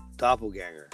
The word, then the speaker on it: doppelganger: And I said it wrong. It's a doppelganger.